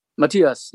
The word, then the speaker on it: Matthias: Am I pronouncing his name right? – Matthias, yeah.